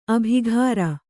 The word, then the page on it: ಅಬುಕಾರಿ: Kannada